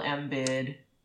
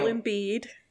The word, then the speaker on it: Embiid: Joel Embiid. – Joel Embiid.